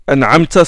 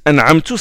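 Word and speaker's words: أَنْعَمْتَ: Donc, la prononciation des mots comme ils sont, le respect des voyelles au lieu de dire an'amta, certains disent an'amtu, ça c'est grave.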